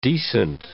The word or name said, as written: decent